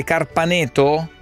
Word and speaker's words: Carpaneto: Carpaneto, ho detto bene, Carpaneto? – Sì.